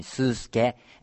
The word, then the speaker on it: スースケ: So, I'm Tadayuki and I have a nickname Susuke and it is the other pronunciation of my, it's a Chinese character of my real name.